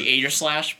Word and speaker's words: Aegislash: Because I would just say Aegislash, but I guess that G is definitely a little bit softer.